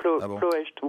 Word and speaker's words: Ploiești: Ouais, on ne prononce pas le i final en roumain, j'ai appris ça. Donc c'est Ploiești ou Ploiești quand même.